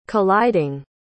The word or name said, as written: colliding